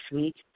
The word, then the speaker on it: Suite: My name is Sweet, spelled like the hotel suite, S-U-I-T-E, Franchon, F-R-A-N-C-H-O-N.